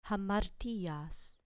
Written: amartias

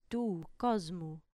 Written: tu cosmu

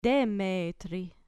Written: demetri

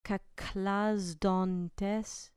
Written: kachlasdontes

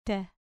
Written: te

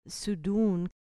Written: su dun

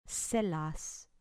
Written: selas

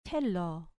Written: thelo

